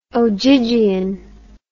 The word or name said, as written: ogygian